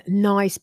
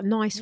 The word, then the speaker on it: Nice: But when Queen Victoria visited the French Riviera in 1882 and took the biscuits with her, the pronunciation was officially changed to Nice because the French city Nice.